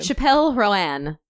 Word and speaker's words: Chappell Roan: Did you say Chappell? – I don't know how to pronounce that person's name. – Chappell Roan. – That's what it is? – No, it's Chappell Roan. – Did I get anywhere near it? – You said Chappell. You were close.